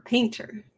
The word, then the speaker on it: Paynter: Cinematography is Robert Painter, but it's spelled P-A-Y-N-T-E-R, Painter.